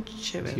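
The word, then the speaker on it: Cheever: de un escritor americano que se llama John Kever, no sé si se pronuncia así. – John Chever.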